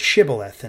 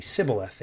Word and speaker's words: Shibboleth: And so they would have to say this word, Shibboleth, and because they had an accent, for whatever reason, they would say Sibboleth.